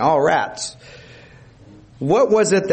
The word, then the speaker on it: arats: That is the word A-R-A-T-S. And it's pronounced all rats.